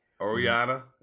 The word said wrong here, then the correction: Ocarina: Is that how you're pronouncing it? – Ocarina? – Ocarina of Time.